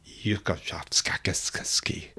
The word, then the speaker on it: Ussachevsky: His name is Vladimir Yukachovskakisky. – That's beyond apologies. – That you may not know, that's the true Russian pronunciation.